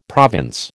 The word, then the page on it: province: American English